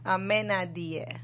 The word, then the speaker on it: Amenadiel: And who knows, she can also be a tool for the people in hell, the father or Amenadiel. Am I saying it right? Amenadiel. – Yeah, it's sounding good.